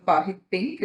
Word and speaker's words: Pahit Pink: But you've also got the Pahit Pink, am I saying that right? – Yeah.